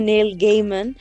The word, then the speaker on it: Neil Gaiman: El creador Neil Gaiman, ¿está bien dicho? ¿Sí? Sí, yes. yes, yes. Sí, confirmamos que el creador se pronuncia Neil Gaiman,